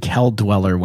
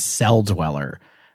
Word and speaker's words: Cell Dweller: I can't believe you just called this person Kel Dweller when it's Cell Dweller. Why would that be a hard C? – You're right.